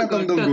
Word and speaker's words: Godot: Iyalah, dibacanya Godot. Godot. Harus medok ciri khasnya.